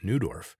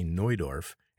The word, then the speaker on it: Neudorf: Here in Canada, we pronounce it Neudorf, but in German they say Neudorf, and in German it means the new town.